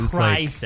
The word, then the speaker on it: Crysis: It's spelled C-R-Y. – Ooh, it's Crysis, so it's Crysis. Like crying.